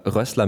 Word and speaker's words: Rössler: Es gab gerade technische Probleme und mir ist nämlich dann aufgefallen, dass du ja Rössler mit 2 S geschrieben wirst und ich habe dich aber Rösler ausgesprochen, das ist ganz falsch.